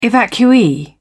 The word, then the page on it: evacuee: British English